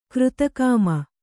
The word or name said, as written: ಕೀರ್ತಿಕಾಮ